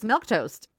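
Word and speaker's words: Milquetoast: The correct pronunciation of milk toast is milk toast.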